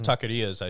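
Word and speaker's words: taquerías: Like, I've been to fucking, like, even, like, some taquerios, mm-hmm. or taquerias, I should say.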